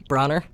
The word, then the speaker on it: Brawner: Last name is Brawner. – Brawner? – Austin Brawner, yep. Are you sure it's not Browner? – I'm sure.